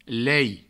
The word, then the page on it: lei: provençal